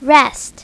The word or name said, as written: rest